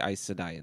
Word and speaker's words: Aes Sedai: So in it, there's this, there's these mage women, and they're called Aes Sedai, A-E-S, and then S-E-D-I-A-I. And I never knew how to say it, and they say Aes Sedai in this, so...